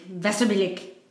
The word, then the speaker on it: Wasserbillig: I'm just going to pronounce W's with V's because I think it's appropriate. – Okay, go for it. – So it begins in Wasserbillig. Wasserbillig.